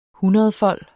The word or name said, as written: hundredfold